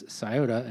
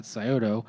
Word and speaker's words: Scioto: Another hot topic around here has been how to pronounce Sciota, which I was wrong about in a previous podcast, but I now am on board with it as Sciota and not Scioto.